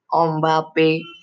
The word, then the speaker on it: Mbappe: Kylian Mbappe Lottin, L-O-T-T-I-N, is his full name, pronounced as Kylian Mbappe,